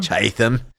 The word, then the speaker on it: Chatham: He was born in Portsmouth, and then in 1817, he moved to Chatham. – Chatham? – Chatham. Yeah, we got to say it like that.